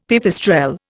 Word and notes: pipistrelle: Indian

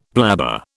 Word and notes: blabber: British English

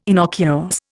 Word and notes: innocuous: Indian

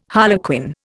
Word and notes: harlequin: Indian

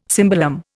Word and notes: cimbalom: Indian

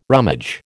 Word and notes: rummage: American English

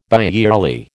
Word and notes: biyearly: American English

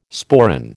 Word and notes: sporran: American English

